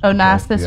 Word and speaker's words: Onassis: Onassis, right, yeah.